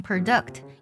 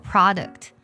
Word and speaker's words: product: 这个单词呢，好多同学可能会误读成 product。一定要要改过来啊，它都做 product，所以呢，国货我们就可以用 domestic products 来表示。